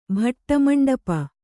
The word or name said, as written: ಭಟ್ಟಮಂಡಪ